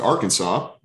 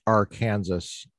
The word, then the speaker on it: Arkansas: To Arkansas, where I'm from, and wrote my dissertation there for a year. – I think it's pronounced Arkansas.